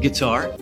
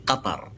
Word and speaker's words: Qatar: First of all, it's the official pronunciation, which is Qatar. So it's a strong Q, and the way that you do it is by trying to close your throat.